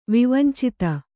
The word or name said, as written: ವಿವಂಚಿತ